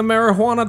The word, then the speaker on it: marijuana: Joe's taking the marijuana to court today, guys. – I like how you pronounce the H. – Marijuana. – Marijuana.